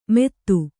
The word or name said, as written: ಮೇದು